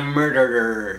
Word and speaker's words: Murderer: Making a murder. – Te falta una sílaba. Sí, es un poco difícil. Si Making a murderer.